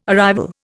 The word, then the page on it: arrival: Indian